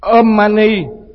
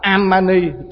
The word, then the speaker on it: Om Mani: Om Mani mà bắt đọc là Am Mani, chết rồi.